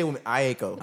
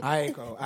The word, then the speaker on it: Aiko: I say Aiko. – Aiko. There you go.